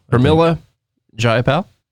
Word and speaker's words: Pramila Jayapal: Okay, number two, I'm going to say, is it Pramila? Pramila Jayapal? I don't know how to say her name. Okay, I'll just be honest. – Pramila. – Pramila Jayapal. – That sounds good.